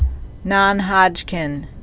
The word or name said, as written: non-Hodgkin's lymphoma